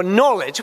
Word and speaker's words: knowledge: All right, moving on now to the K, T-A-K-E-S. The K is for knowledge. Of course, it's a silent K, but it's still a K, okay?